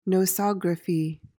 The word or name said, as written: nosography